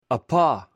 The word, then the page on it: apo: greek